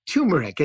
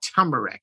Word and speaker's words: turmeric: And I know it's turmeric. It's not turmeric.